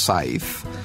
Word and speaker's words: Saiz: It's a Spanish name, and it's pronounced scythe, exactly like the agricultural tool you use to mow the grass. – But it's spelled S-A-I-Z. – S-A-I-Z, yeah.